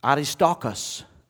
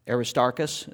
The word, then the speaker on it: Aristarchus: Aristarchus is how you say that. Aristarchus is what we'll say here in Texas.